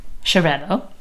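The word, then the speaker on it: Xarel·lo: Chirello. – Yeah, so the X is like a ch.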